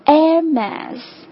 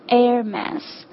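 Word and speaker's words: Hermès: OK，第三个我们要看的也是现在最炙手可热的一个牌子，爱马仕哈，爱马仕正确的发音是 Hermes，这个第一个音其实就是跟空气 air 的音是一样的， Hermes，这是一个法语哈，但是 Hermes 这个是在所有的英语国家当中最最广为接受的一种发音的方法， Hermes。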